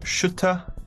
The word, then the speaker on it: Schütter: Is it Julian Schütter? – Yeah. It's pronounced Schütter in German, but... – Okay. – Like, you don't have that sound, the U.